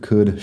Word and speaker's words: could: porque se escriben parecidas y todos tienen la L que no suena y el mismo sonido de la U corta. Así que would, could, should.